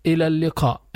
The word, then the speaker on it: إلى اللقاء: إلى اللقاء, إلى اللقاء. ألف همزة under the ألف, لام and ياء مقصورة, which is pronounced as an ألف.